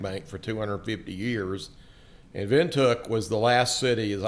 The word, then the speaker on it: Windhoek: It's called From Welcome to Windhoek. – To Windhoek.